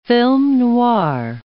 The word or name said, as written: film noir